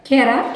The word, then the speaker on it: Kera: Kera. – Kera. – Kera means coconut. – How do you spell it? – K-E-R-A.